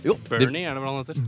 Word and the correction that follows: Byrne: Ja, han har skrevet med Byrne, vet du. Mm. David Byrne. – Ja, det tror jeg var perfekt uttale.